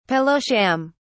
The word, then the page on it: pillow sham: British English